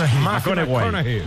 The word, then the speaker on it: McConaughey: Que hay que ir corrigiendo la pronunciación. McConaughey.